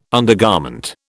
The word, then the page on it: undergarment: British English